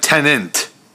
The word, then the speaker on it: Tenet: You're saying it like Tenet. – How do you say it? – Tenet. Because it's T-E-N-E-T.